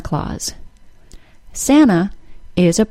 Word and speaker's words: Claus: The vowel sound in Santa's last name, Claus, is pronounced with the A-W sound, aw. C-L-A-U-S-E and C-L-A-W-S, like a cat has, are homophones and are pronounced exactly the same.